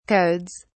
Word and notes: codes: British English

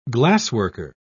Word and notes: glassworker: British English

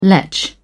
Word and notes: lech: British English

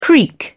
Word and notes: creek: British English

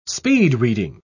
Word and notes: speed-reading: British English

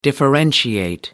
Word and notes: differentiate: American English

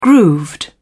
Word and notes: grooved: British English

